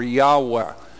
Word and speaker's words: Yahweh: he began to use the name Jehovah or Yahweh, would be probably closer to the Hebrew pronunciation.